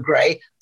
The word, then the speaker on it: Gray: My name is indeed Trevor Gray. It is spelt with an A, by the way, G-R-A-Y.